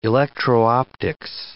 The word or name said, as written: electro-optics